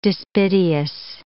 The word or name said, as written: despiteous